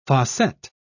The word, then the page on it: fossette: British English